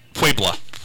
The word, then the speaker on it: Puebla: It doesn't sound Spanish, but your caller is right. It's Puebla. – So, Puebla. – Pue. – Pue. – Not Pue. – Pua. Pue. Puebla. Puebla. The Battle of Puebla.